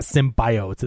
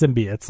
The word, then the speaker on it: symbiotes: But like, it's not symbiotes, it's symbiotes.